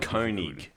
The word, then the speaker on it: Koenigsegg: Let me get the pronunciation correct, because I always have Koenigsegg. – That's right.